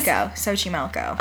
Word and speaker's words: Xochimilco: I cheated and looked up the pronunciation. – Oh. – Because I didn't... It's like, it's got X's and H's and... – The little ticks over the E's. – Xochimilco.